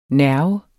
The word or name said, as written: nerve